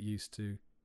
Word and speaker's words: used to: The pronunciation of get used to can be difficult. We don't pronounce the ed part of the word used.